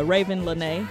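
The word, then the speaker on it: Raven Lenae: This is Sticky by Raven Lanae. Is that how you say that? – Yeah. – Okay, all right.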